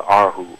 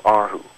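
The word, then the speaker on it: erhu: It's actually called an arhu. R is two, and hu is like a string, so arhu.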